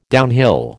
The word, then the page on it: downhill: American English